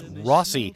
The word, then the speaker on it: Rosi: Now, Rossi was after that, and actually, I mispronounced that. That's Rossi. It's spelled R-O-S-I.